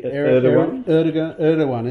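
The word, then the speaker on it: Erdoğan: Erdogan, is it? Okay. – No, I think I've got it right. Erdogan.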